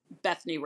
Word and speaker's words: Bethny: Uh, Jesus led, Bible fed, and my writing account, which is Bethny Ricks.